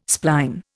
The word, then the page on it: spline: Indian